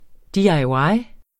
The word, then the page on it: DIY: diɑjˈwɑj